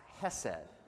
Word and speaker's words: hesed: It's the Hebrew word that's pronounced hesed.